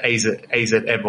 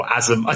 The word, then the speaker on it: AZM: And the name is written, it is stylized as AZM. – Okay. – And I think Mercedes pronounced this as, I don't know how she pronounced it, AZM or Azum. I don't know what she said, but it's pronounced Azumi.